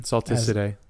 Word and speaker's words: Salticidae: Yes, it's in the family Salticidae or Salticidae. – I think you're right on the second one. – With the soft C. – Mm-hmm. Salticidae. – As it's soft C today.